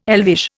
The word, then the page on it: elvish: Indian